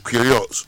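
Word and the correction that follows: Queiroz: Kurosh. – I mean Kurosh.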